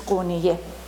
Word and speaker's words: Konya: So they settled into this area called Konya, now is in Turkey, and still they call it, the pronunciation is Konya.